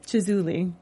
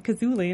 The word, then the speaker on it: Chizuli: How do you pronounce it? – Chizuli. – Okay. – Because Melissa said Kazuli, and I'm like, you see their commercials. You're from New Jersey. How are you pronouncing it wrong?